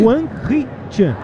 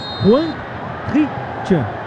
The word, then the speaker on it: Hwang Hee-chan: Como, viu, é, como é que fala o nome do cara aí? – Hwang Hee-chan. Hwang Hee-chan.